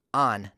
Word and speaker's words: han: As, h-a-s, h-a-n, on, not han.